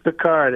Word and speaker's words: Picard: Is it Picard? Is it Pickard? Is it, what is it? – It's Picard. It's like the captain.